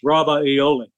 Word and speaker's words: Ravioli: See, it's actually spelled different. It's to pronounce it correctly, it's Ravioli. But who's going to remember that? They're going to say ravioli.